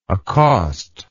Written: accostable

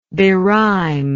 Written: berhyme

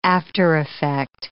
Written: aftereffect

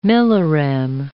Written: millirem

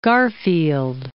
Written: Garfield

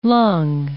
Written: lung

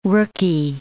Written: rookie

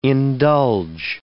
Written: indulge

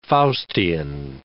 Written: Faustian

